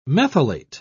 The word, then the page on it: methylate: British English